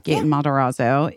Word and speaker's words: Matarazzo: Yeah, Gaten Matarazzo in Dear Evan Hansen.